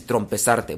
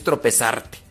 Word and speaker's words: tropezarte: Reconoce el error, fíjate en dónde te tropezaste Dicen que no se dice tropezarte, se dice trompezarte. Bueno, trompezarte allá se dice en mi rancho, pero es tropezarte.